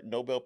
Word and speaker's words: Nobel: Did I say it right? Nobel Peace Prize? – Yeah.